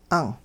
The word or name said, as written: 'ang'